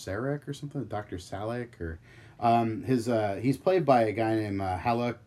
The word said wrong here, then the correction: Haluk: Beligner. Just butchering the shit out of his name. – Haluk Beligner. – Haluk Beligner. – It's Turkish.